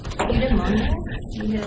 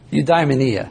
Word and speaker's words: Eudaimonia: Eudaimonia? – Close. Eudaimonia. I think it doesn't even have a little accent here. So I can't really see it. Oh, yeah, here it is, like right here, right? Eudaimonia.